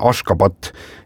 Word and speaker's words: Ashgabat: Ja selle pealinna tänapäevane õige kirjapilt ja hääldus on Ashgabat ja siis 1991.